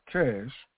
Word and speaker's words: CSH: The successor to the Mobile Army Surgical Units, also known as MASH, is the Combat Support Hospitals, CSH, also known as CASH. Now let me read about that. Combat Support Hospital. Combat Support Hospital, CHS, pronounced CASH, is a type of modern U.S.